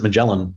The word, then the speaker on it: Magellan: Yeah, here in Austin, we pronounce it Magellan. But I, you know, it's the, and it named after the explorer, right? And so the pronunciation of his name is, depends on where you live, right? The Spanish pronunciation is different again.